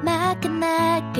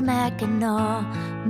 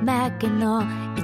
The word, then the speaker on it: Mackinac: You know it's not pronounced Mackinac, it's Mackinaw, Mackinaw. It's an Indian name, but it almost sounds French.